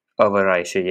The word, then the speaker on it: Overijse: Is that how you say it? Overijse? Overijse? Overijse. Overijse, yeah, I had a bang on.